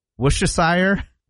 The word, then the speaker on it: Worcestershire: So you don't say the R, you don't say Worcestershire? – No. – Worcestershire. – Worcester. – Worcestershire. – Yeah, I'm going to go to Worcestershire.